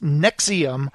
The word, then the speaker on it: NXIVM: Keith Rainier, the leader of the cult known as NXIVM, pronounced Nexium, was taken into custody by Mexican federal police on Sunday and extradited to the U.S.